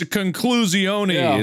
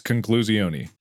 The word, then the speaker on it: conclusione: It's conclusione is actually literally how you say it in Italian. I'm not joking. It's conclusione.